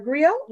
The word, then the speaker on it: griot: So they have these griots, or and it's G-R-I-O-T, but it's pronounced griot, or Djelli, D-J-E-L-L-I.